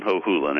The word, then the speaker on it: Hohulin: The easiest way to remember it is it rhymes with no fooling. So no fooling, Hohulin, and you've got it.